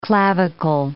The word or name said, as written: clavicle